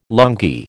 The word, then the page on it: lungi: American English